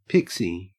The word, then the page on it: pixie: Australian English